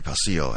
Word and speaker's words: Palacio: As I was saying a little earlier, coming up next, Andy Palauco. And, or Andy Palacio, actually. Palacio. Excuse my pronunciation.